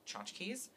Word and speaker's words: Tchotchkes: On Monday, instead of going to work as usual, he goes straight to the restaurant where Jennifer Aniston's character works, called Tchotchkes, although not spelt the way it's supposed to be spelled. – No. – It's spelt with C-H rather than a T. Yeah. Yeah, there's a silent T.